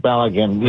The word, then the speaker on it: Balogun: Balogun.